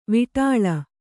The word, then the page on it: ವಿಟಾಳ: viṭāḷa; Kannada